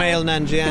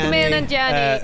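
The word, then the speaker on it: Kumail Nanjiani: And then Dave's obsessed with, how do we say his name, Kamal? – Kamal Nanjiani. – Kamal Nanjiani.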